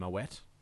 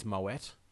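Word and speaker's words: Moët: So and you get some common ones that I think aren't even worth correcting, like Moe and Moet. – Yeah. – Technically it's Moet. – Yeah. – But everyone says Moe.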